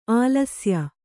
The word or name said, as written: ಆಲಸ್ಯ